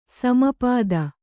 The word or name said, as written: ಸಮಾಪ್ತ